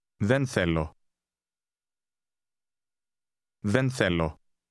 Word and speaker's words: θέλω: Did you hear the two different TH sounds? Listen and repeat to practice your pronunciation. – Θέλω. Δεν. Δεν θέλω. – It's like the difference between the words then and think.